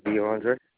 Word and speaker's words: DeAndre: My name is DeAndre.